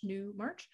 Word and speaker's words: Newmarch: My Twitter is Joe Newmarch, just Jonewmarch, just how it sounds. Month of March, Newmarch. And same with Instagram, Johannah Newmarch.